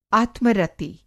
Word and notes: आत्मरति: Hindi

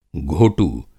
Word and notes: घोटू: Hindi

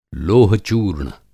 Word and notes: लोह-चूर्ण: Hindi

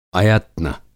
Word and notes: अयत्न: Hindi

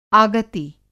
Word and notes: आगति: Hindi

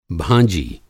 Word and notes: भाँजी: بھانجی

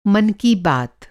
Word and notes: मन की बात: Mann Ki Baat

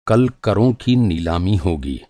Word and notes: नीलामी: نیلامی

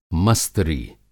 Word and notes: मस्तरी: Hindi

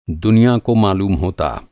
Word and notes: मालूम: معلوم

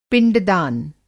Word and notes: पिंडदान: Hindi

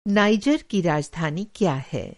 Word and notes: नाइजर: نائجر

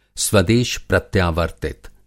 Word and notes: स्वदेश प्रत्यावर्तित: Hindi

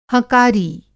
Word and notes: हँकारी: Hindi

